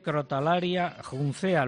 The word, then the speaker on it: Crotalaria juncea: Es la empresa que ha introducido en España la especie Crotalaria juncea. ¿Lo he pronunciado bien? – Correcto, lo pronuncia muy bien.